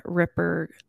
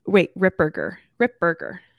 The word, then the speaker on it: Ripperger: Here, you're right. Father Chad Ripperger. Rip Berger. Yep.